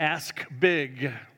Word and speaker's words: ask: Make sure you pronounce the K. I'm going to ask big.